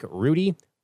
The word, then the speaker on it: Rhudy: My personal X account is at Nick Rhudy, R H U D Y is how you spell that last name.